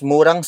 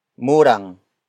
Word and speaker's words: murang: Murang. Murang. Murang is spelled as M-U-R-A-N-G.